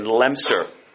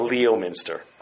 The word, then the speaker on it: Leominster: Notice that's what I'm told is the pronunciation if you're there. It's not Leominster. One of the glories of the English language is that words aren't pronounced the way they look. And I think that's glorious, but anyway.